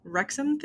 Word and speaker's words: Wrexham: But again, he could throw him off his game so much that, because I was watching the Wrexham thing.